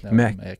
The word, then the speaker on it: Mek: Den godeste Emil Mek, er det sånn du uttaler det? Er det Mik, eller er det Mek? – Mek.